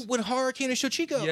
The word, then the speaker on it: Shochiku: Shochiku? – Yes, Shochiku.